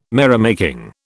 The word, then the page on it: merrymaking: British English